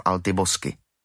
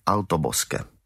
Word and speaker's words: Altobosque: Všichni by měli mít plno práce v Altibosky. Vyslovil ten název bez ohledu na titěrnosti iberionské výslovnosti, jak bylo jeho zvykem. Dotyčným městem bylo vlastně Altobosque.